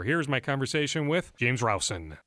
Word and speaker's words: Rowson: I thought it was Rosen, but Levine went Rousen. We'll go James Rousen.